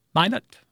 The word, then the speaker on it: Minot: Did I say that right? Minot? Mint? – You did.